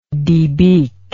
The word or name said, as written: debeaker